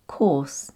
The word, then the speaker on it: course: In this case, the O-U-R is pronounced or, so it becomes course. And it's the same for court, C-O-U-R-T, too.